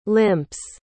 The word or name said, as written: limps